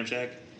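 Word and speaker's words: Ramczyk: Their tackles are probably one of the better two tackle tandems in the league with Teron Armstead and Mike Ramchak, or Ryan Ramchak, I think is how you say his name. – Yeah, Ramchak.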